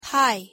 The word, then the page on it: hi: English